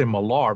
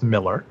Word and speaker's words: Millar: In Miller's newsletter, you know, some people say Millar, but it's actually pronounced Miller.